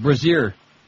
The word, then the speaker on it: Brazier: You got to say it right, Brazier.